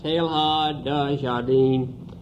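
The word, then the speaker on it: Teilhard de Chardin: Marie-Joseph Pierre Teilhard de Jardin, give it the English pronunciation, was born on May the 1st, 1881, in central France, a few miles from Clermont.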